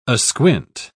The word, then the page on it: asquint: British English